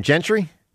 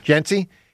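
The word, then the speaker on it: Jeanty: The running back, how do you say his name, Gentry? – Genty.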